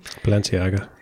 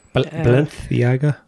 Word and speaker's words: Balenciaga: I don't know how to pronounce the Balenciaga. – Balenciaga. – Yeah. – Spanish. – That. – Balenciaga.